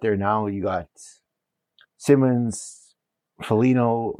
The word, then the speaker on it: Foligno: Foligno.